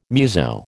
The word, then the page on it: muso: American English